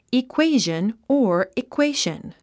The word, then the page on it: equation: ikwéiʒən; イクエイジャン